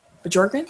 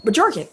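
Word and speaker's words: Bjorkgren: I think the B might be silent. – It's not. – It could be, but – oh, it's not? – No. – So is it Bjorken? – Bjorken.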